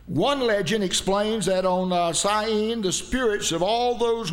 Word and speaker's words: Samhain: And they called it, I pronounce it Samhain, but that's not the way they say it. They say Samhain.